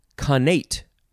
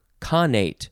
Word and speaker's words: connate: Next word, conate or conate. Emphasis on either syllable, C-O-N-N-A-T-E.